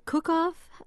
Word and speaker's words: Kuckhoff: Well, one of them, you found out, was Greta Kuckhoff, am I pronouncing that correctly? – That's right.